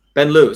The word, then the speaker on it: banlieue: Banlieue.